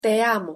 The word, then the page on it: te amo: Spanish